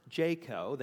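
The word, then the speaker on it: JCAHO: They're pronounced JCO.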